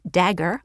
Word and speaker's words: Dagger: I am sure I'm pronouncing it right because she said it's Dagger, like the knife.